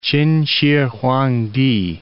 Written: Ch'in Shih Huang Ti

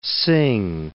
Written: Singh

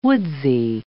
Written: woodsy

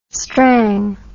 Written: strang

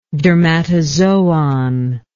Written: dermatozoon

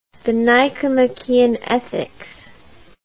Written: Nicomachean Ethics, The

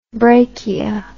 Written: brachia

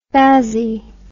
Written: spassy

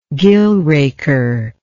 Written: gill raker

